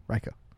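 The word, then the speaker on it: Reyka: Ryka. Did I say that right? – Yeah. Okay.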